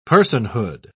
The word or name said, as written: personhood